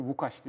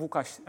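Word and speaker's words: Łukasz: Hatta işte Podolski'nin ismi L aslında Lukas değil. L'nin üstündeki çizgi Vukaş. Evet. Vukaş Podolski diye okunur.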